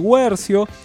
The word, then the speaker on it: Guercio: Emilio del Guercio se escribe con diéresis, pero la U sí se pronuncia. O sea, se escribe sin diéresis, pero la U... ¿Se entendió o no? – Sí. – Se escribe sin diéresis, la U se pronuncia. – Claro, es como pingüino o como agüero, pero sin la diéresis.